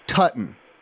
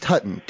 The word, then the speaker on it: Tutone: Tutton. T-U-T-O-N-E.